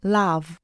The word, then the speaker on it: love: You pronounce this word with the sound ah, not o, but ah, love and I know I can see every possibility.